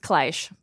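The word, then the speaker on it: cliché: I mean, that's cliche. Like, anyone would have that dynamic. – I believe it's pronounced Kleish. – Kleish. – It's Kleish.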